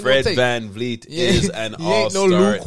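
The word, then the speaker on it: Fred VanVleet: All-star Fred Van Vliet. Make sure you say it right, because they say if you don't say it, they're pure idiot out there. They can't say his name.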